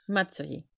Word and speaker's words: Matsuri: Das ist das, ich glaube, ich habe Matsuri, ist es Matsuri oder Matsuri? – Matsuri. – Matsuri, dann habe ich es letztes Mal falsch ausgesprochen.